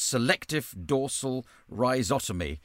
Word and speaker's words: selective dorsal rhizotomy: which you've put it down as selective dorsal rhizotomy. I hope I'm pronouncing that correctly. – Yes.